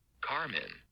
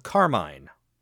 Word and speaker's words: carmine: Carmen. – Okay. Carmen. – No idea. What is that? – Well, it's spelled Carmine. – Oh. – Which is, I think is probably a valid pronunciation.